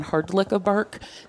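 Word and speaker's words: Hrdlica-Burke: I'm also, a lot of people know me as Dawn Hardlick of Burke.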